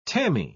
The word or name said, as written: tammy